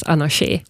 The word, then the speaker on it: Anarché: Och som uttalas Anarché, vilket då med accent på sista e-et ibland har varit ett problem för vissa.